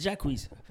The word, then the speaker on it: Jacquees: It's Jacquees.